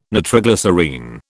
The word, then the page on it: nitroglycerine: British English